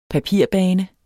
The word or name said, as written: papirbane